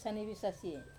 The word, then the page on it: sanevesasie: Wamesa